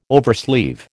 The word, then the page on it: oversleeve: American English